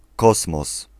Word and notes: kosmos: /ˈkɔsmɔs/

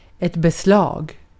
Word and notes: fitting: /fɪ.tɪŋ/

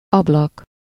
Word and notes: fenêtre: France; [yn fø.nɛtʁ̥]; /fə.nɛːtʁ/; /fə.nɛtʁ/

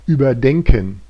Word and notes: überdenken: /yːbɐdɛŋkn̩/

